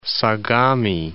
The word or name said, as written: Sagami Sea